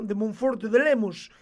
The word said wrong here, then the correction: Lemos: Y, ¿Qué es eso? – Monforte de Lemos se llama el pueblo de ah, mi. Ah, de Lemos. Porque se pronuncia así, ah. Es galego.